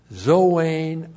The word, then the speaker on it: zoein: And then we have the outcome which is declared zoein ionion. Z, long O, long E, N, Zoein, that's the accusative case as a direct object.